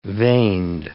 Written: vaned

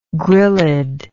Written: gryllid

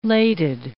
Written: lated

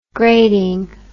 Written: grading

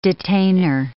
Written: detainer

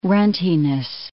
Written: runtiness

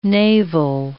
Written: naval

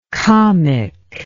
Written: kamik

